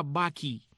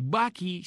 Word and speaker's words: bakkie: También se le llama Bucky en Sudáfrica. Y Bucky se escribe B-A-K-K-I-E,